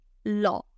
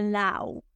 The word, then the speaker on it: law: Lo que ocurre aquí es que ocurre algo bien particular, que es que la A y la doble U se pronuncian como si fuera una O. Esto ocurre muchísimo porque hay muchas combinaciones de diptongos en inglés que cambian la pronunciación. Entonces, ley no se dice lao, se dice lo.